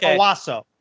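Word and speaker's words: Owusu: That's the proper way to pronounce his name. Patrick Owosu. Owosu. It's Patrick Owosu.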